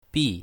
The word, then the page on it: bi4: mandarin